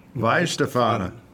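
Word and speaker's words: Weihenstephan: But I have to say that if I wanted to have a beer, was it Weinstefan? How do you pronounce it? – Weinstefan. – Weinstefan. Right.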